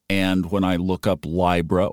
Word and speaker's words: Libra: And we scroll down to Libra, and it is pronounced Libra, I as in bind or time.